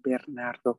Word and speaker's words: Bernardo: And we had a gentleman that worked for us, Bernardo, that's how you say it in Spanish, Bernardo.